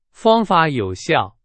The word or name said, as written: 有效